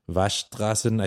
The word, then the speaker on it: Waschstraßen: Waschstraßen. It's got one of those... she said I people don't pronounce it that clearly, so it's more like Waschstraßen. I think you just leave the S out.